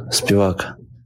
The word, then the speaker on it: Spivak: Sergey the polar bear Spivak. – Spivak. – Spivak. Spivak. Spivak. – I mean, I hear Spivak. You know, I actually, as long as you're emphasizing the first syllable, I don't really think it matters.